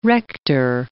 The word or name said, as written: rector